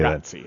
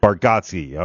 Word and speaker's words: Bargatze: And I always want to put a K in there. It's Bar. How did he say Bargatze. – That? Bargatze.